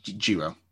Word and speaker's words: Jiro: We've got Andre Chase, Trey Baxter, Duke Hudson, Josh Briggs, Joe Gacy, Ikeman Hiro? – Jiro. – Okay, Jiro. We can pronounce the J.